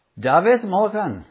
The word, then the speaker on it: David Morgan: And there was one particular sermon which David Morgan preached, and people said, David Morgan, that's how you say it in Welsh, David Morgan, where did you get that sermon?